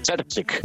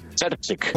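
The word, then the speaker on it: Terzić: Terzic. – Una vez más. – Terzic.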